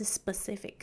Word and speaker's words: specific: 那像我现在在录音的时候就会特别明显，很像就是你知道要喷口水的感觉，所以为了不要让你就是整个气都你知道漏出去，这个我们在发音上面呢就会把它念成是 specific，但是呢在音标上面的部分呢还是会就是标注 P，那自己就是可以稍微注意一下。